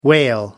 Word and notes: whale: American English

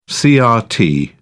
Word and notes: crt: British English

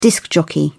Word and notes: disc-jockey: British English

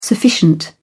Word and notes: sufficient: British English

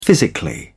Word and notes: physically: British English

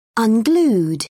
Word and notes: unglued: British English

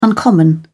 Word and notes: uncommon: British English

